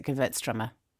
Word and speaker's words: Gewürztraminer: They pronounce it Gewürztraminer, but obviously that's not right. You're pronouncing it German way.